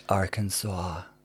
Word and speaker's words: Arkansas: It's pronounced Arkansas.